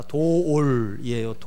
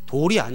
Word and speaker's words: 도올: 예, 발음이 중요합니다. 도올이에요. 도올. 돌이 아니에요. 돌이 아니고 도올.